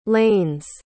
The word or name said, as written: lane's